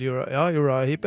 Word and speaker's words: Uriah Heep: Har du hørt om en gruppe som heter, er det Uriah Heep, uttaler jeg det riktig? – Ja, det er vel Uriah Heep, ja.